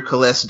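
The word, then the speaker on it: Cales: And you can find me on Facebook under Kales Davis. And it's not spelled with a K. It's a C. So many people have like tried to spell my name with a K-A-L instead of C-A-L.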